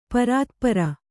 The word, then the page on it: ಪರಾತ್ಪರ: parātpara